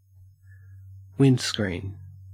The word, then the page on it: windscreen: Australian English